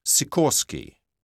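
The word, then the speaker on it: Sikorski: That's W-I-L-L-I-A-M-S. My first name is Paul. – 3. – And what's your family name? – Sikorsky. That's S-I-K-O-R-S-K-I.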